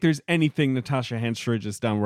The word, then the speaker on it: Henstridge: Henstridge? – Henstridge.